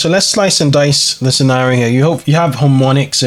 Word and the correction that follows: HoMedics: Homedics, and they're like a manufacturing company, right?